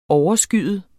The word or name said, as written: overskyet